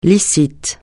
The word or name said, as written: licite